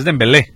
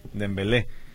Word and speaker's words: Dembélé: ¿cómo se pronuncia el apellido del jugador Dembélé? Porque tiene dos acentos el apellido, y por lo regular solo, pues lo regular es uno, exactamente, es Dembélé. Se hace con la pronunciación en el segundo, ¿verdad?